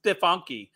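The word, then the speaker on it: Stefanik: Postal workers stole $20,000 in donation checks from GOP Congresswoman Elise Stefanke.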